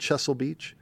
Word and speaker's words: Chesil Beach: Last one, on Chesil Beach. Did I pronounce that correctly? On Chesil Beach? – You did.